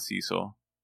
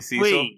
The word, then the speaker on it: Cecil: His name is Cecil, not Cecil. – I hate that. – You hate when people say Cecil? – Wait, it's not.